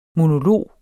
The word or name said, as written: monolog